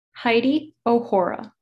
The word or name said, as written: Heidi OHora